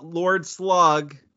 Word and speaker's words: Lord Slug: If you check out Lord Slug, did I say that right? – Yep.